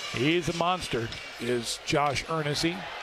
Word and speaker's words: Eernisse: How would you pronounce the name if you were a listener? E-E-R-N-I-S-S-E. And it is Ernesy.